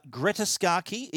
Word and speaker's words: Scacchi: Greta Scacchi plays June Goodman's daughter.